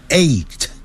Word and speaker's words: eight: eight 那个好念 gh 不发音念成 eight